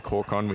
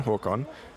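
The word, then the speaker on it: Håkon: So sometimes you can have a name like Hawkon, which is a H-A-O thingy over the top, K-O-N, Hawkon. But sometimes if it's anglicized, it's H-A-A-K-O-N. And sometimes they just spell it that way.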